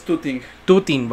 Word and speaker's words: tutting: Ah, él se dedica a algo que, se llama tweeting animation, ¿sí lo dije bien, güey? Es, es tooting.